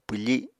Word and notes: pwfi7: Apatani

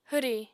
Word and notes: hwri: apatani

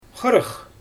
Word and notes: xɯrɯx: Xyzyl

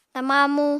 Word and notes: tama amu: apatani

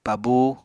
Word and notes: pabú: Apatani

